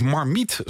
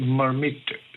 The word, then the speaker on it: Marmite: Ik denk namelijk dat hij uit het Frans komt. En dan zou het Marmite zijn. – Oh, Marmite. Zoals petit.